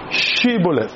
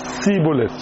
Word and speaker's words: Shibboleth: If you wanted to identify these guys from Ephraim, you tell them, say, Shibboleth. Then, the Bible said they could not pronounce Shibboleth. They say, Sibboleth. So, their SH sound, S.